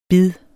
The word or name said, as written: bid